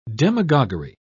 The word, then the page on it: demagoguery: British English